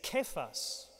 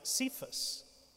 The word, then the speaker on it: Kephas: And the name is Kephas, or we usually pronounce Cephas, which is Aramaic for rock.